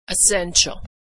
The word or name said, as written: essential